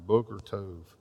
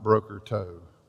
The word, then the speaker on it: Boker Tov: Boker Tov is how you say good morning in Hebrew. Try that out tomorrow. Boker Tov, not Broker Tov.